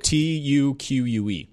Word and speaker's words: tuque: We in our announcement story, I had a nice interview with the founder of that studio, coming from Took Games, I believe Montreal based. – So the little hat with the fuzzy ball on top. – That's a Took. – That's a Took. – Tuque.